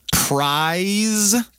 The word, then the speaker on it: prize: Notice the difference, Sandra, in pronunciation between prize. – Con una Z escrito, which means premio. – And price.